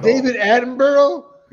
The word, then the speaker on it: David Attenborough: David Attenborough? – Yeah, very nicely pronounced.